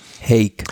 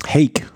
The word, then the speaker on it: Haig: Und da hat Pogacar den Sack zugemacht und ist dann mit 6 Sekunden Vorsprung vor Jack, wie spricht man aus? Jack Haig, glaube ich, ne? – Haig. – Haig. Jack Haig.